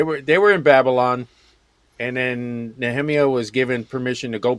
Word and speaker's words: Nehemiah: Well, it's the correct way to say it, Nehemiah.